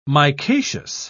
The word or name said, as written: micaceous